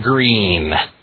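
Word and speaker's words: Gruen: So you have, for example, there's a town called, it's spelled G-R, I think it's G-R-U-E-N. They call that Green. There's a town spelled S-E-G-U-I-N.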